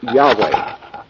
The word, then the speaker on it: Yahweh: In the Old Testament, the word that is translated God is a word called, pronounced Yahweh.